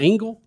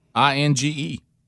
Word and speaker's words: Inge: L. There's no L. Inge. – I think they still say Engel. – Do they really? – Maybe they left the L off. – Yeah. So it said he was a just. Inge? He was a. It's spelled INGE. – Yeah. – Inge? – Let's see what it sounds. That makes more sense.